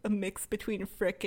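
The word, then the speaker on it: Frik: Him Babu Freak, by the way, because I think it's pronounced Frick, but now I'm imagining it's like Babu Freak, like, get freaky on the dance floor.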